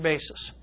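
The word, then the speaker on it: basis (βάσις): And that Greek word is basis. It's pronounced just like our word basis.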